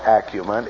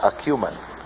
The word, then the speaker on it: acumen: It is not acumen. It is acumen. It's the correct pronunciation.